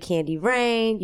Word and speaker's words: Candy Raine: And of course, I'm DJ Candy Rain. You can find me across all social media platforms at My Candy Rain, or just search Candy Rain, but spell it C-A-N-D-Y-R-A-I-N-E. Don't forget it, baby.